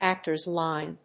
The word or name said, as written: actor's line